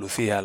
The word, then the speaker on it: LuzIA: y se llama Luz IA, y lo pronuncian Lucía.